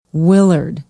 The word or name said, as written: willard